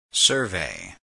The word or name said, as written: survey